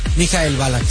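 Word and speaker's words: Michael Ballack: ¿Cómo se dice bien? ¿Mikhail Ballack? – Mikhail Ballack, sí.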